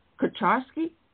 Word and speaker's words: Kucharski: So I want to welcome Mike Kucharski.